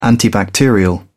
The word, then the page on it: antibacterial: British English